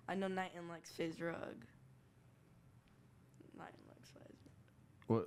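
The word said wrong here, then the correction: FaZe Rug: Phase Rug.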